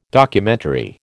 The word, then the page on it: Documentary: American English